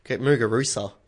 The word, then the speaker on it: Muguruza: At 3, Gabi Muguruza. Muguruza.